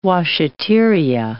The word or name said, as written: washateria